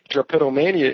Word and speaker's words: drapetomania: Drapetomania.